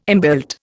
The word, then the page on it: inbuilt: Indian